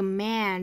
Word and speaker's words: man: The drowning man 后面的这个 man 注意发音是梅花 a，我们可以把两根手指头竖着放到上下齿之间去找这个开口度的感觉 a man。